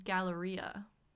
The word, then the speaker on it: Galleria: And I figured out why Galaria didn't sound familiar. It's because her name is Galleria.